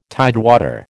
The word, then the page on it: tidewater: American English